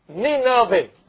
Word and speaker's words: Nineveh: Now, how you pronounce that one? Nineveh.